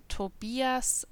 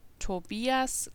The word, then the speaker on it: Tobias: Guten Tag, mein Name ist Tobias Klein.